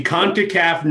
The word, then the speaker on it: CONCACAF: U.S. winning CONCACAF, right? I pronounced that right, maybe? – The CONCACAF Nations League. – CONCACAF. There we go.